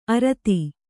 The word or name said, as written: ಅರದ